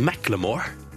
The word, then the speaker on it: Macklemore: At det skal uttales Macclemore.